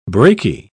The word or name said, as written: braky